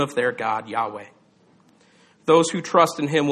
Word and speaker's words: Yahweh: The name he revealed to Moses, we pronounce as Yahweh.